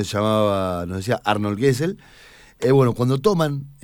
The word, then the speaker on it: Gesell: Gesell es la aproximación a la pronunciación, pero se dice Gesell en general acá.